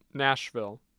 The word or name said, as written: nashville